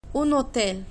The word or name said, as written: un hotel